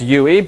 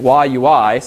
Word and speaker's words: YUI: I previously called this UI, but apparently there's a video on Yahoo's website where one of the developers pronounces it YUI, so I have to retrain myself.